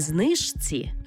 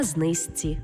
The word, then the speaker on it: знижці: Пишемо «жці», а вимовляємо «зці». Наприклад, Не подружці, а подружці. Не знижці, а знизці. І не у книжці, а у книжці.